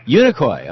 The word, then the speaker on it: Unicoi: In how do you pronounce that town in Tennessee there, where you are calling from? – Unicoi. – Okay, that's what it looked like.